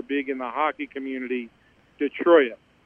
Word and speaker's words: Detroit: It's first of all, it's Detroit, not Detroit, and it's pecan, not pecan.